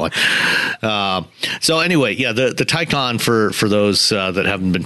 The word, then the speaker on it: Taycan: I'm saying that wrong. It's Taycan, yes. – Taycan. – Porsche says it's Taycan.